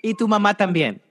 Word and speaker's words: Y tu mamá también: I think it's pronounced E2. – Y tu mamá también. That's the name it's pronounced.